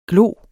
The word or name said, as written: glo